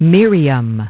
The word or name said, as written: Miriam